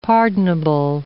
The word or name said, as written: pardonable